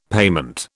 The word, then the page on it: payment: British English